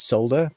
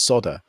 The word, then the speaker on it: solder: Is in England we pronounce the L, so it's solder. But whenever here in the States, it's solder, which makes me laugh because it sounds like sodomized.